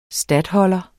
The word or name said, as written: statholder